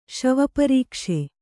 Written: ಶವಪರೀಕ್ಷೆ

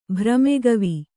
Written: ಭ್ರಮೆಗವಿ

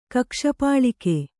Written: ಕಕ್ಷಪಾಳಿಕೆ

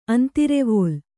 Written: ಅಂತರವಲ್ಲಿ

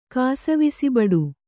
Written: ಕಾಸವಿಸಿಬಡು

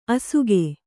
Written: ಅಸುಗೆ

